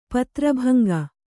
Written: ಪತ್ರಭಂಗ

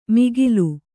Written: ಮಿಗಿಲು

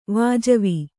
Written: ವಾಜವಿ

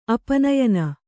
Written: ಅಪನಯನ

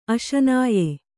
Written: ಅಶನಾಯೆ

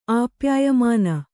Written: ಆಪ್ಯಾಯಮಾನ

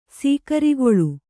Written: ಸೀಕರಿಗೊಳು

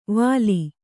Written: ವಲಿ

